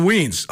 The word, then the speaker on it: Wiens: It's Hudson Wiens.